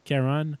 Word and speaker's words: Charon: Charon, pardonne-moi, c'est vraiment la bonne prononciation, c'est Charon.